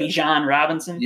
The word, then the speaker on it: Bijan Robinson: Bijan Robinson? – Yes.